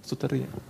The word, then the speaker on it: Soteria: Ist das richtig ausgesprochen? Soteria? Soteria. Soteria. Genau. Soteria. Genau.